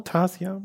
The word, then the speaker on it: Tarsier: Tan, Tan Tansier Studio, heißen sie so? Tarsier. Tarsier heißen sie, genau.